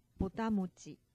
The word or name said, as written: botamochi